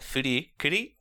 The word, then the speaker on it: Fooly Cooly: do the Japanese pronunciation of the title when they say it. All of the voice actors pronounce it "fudi kudi" every time, no matter what, in English, in the middle of English sentences.